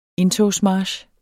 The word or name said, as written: indtogsmarch